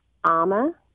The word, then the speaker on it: AAMA: Yes, it has been 50 years in the making, and we actually pronounce it AMA.